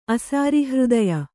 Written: ಅಸಾರಿಹೃದಯ

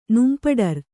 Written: ನುಂಪಡರ್

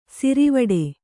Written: ಸರವಟ್ಟಿ

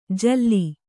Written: ಝೂಲು

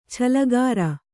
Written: ಛಲಗಾರ

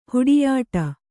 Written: ಹುಡಿಯಾಟ

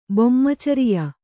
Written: ಬೊಮ್ಮಚರಿಯೆ

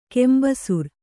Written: ಕಂಬಿಸೀರೆ

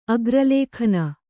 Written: ಅಗ್ರಲೇಖನ

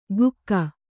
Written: ಗುಕ್ಕ

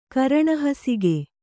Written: ಕರಣಹಸುಗೆ

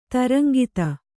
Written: ತರಂಗಿತ

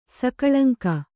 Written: ಸಕಳಂಕ

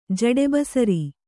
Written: ಜಡೆಬಸರಿ

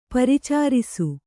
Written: ಪರಿಚಾರಿಸು

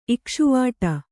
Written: ಇಕ್ಷುವಾಟ

